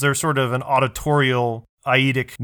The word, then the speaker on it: eidetic: is it eidetic memory or eidetic memory? How do you pronounce it? Eidetic memory? – Eidetic. – Eidetic memory. There we go.